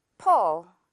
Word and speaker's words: pull: 풀처럼 입모양 만들고 펄이라고 말하는 그런 느낌으로 펄, 펄 이렇게 발음을 해주면 돼요. 그래서 약간 풀 또는 펄처럼 들릴 수가 있어요. 둘 다 가능해요.